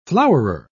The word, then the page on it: flowerer: British English